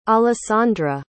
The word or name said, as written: alessandra